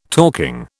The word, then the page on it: talking: British English